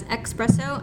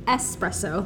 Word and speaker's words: espresso: If you pronounce it as espresso, I'm so sorry. It's actually wrong. That's espresso.